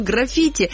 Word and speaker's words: граффити: Вот, и он говорил, заметьте, я все про ударение, он говорил граффити, но я снова возвращаюсь к Википедии, которая гласит, что граффити, и что это слово происходит от итальянского, боже мой, граффито, вот, и вот, в общем-то...